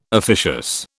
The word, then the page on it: officious: British English